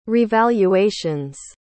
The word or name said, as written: revaluations